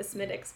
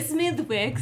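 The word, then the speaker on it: Smithwick's: So, first of all, how'd you pronounce it? – Smiddix. That's right. You're about to be like, it's actually a Smithwix. – Smithwix. No, it's a Smithwix. This is more of a TH, but anyway, so.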